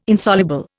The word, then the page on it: Insoluble: Indian